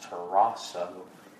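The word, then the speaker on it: terasso (ταράσσω): This English word, troubled, in the Greek is pronounced terasso.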